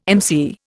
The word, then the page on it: emcee: Indian